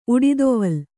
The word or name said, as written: ಉಡಿದೊವಲ್